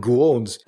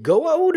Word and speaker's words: Goa'uld: And then he kind of looks at Carter, and Carter says, Carter says Goa'uld, which mm-hmm. is it Goa'uld or is it Gua'uld? I thought it was Gua'uld.